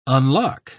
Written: unlock

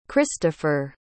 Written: kristopher

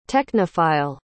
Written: technophile

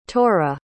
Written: torra

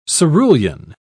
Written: cerulean

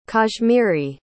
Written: kashmiri

